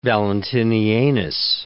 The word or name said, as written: Valentinianus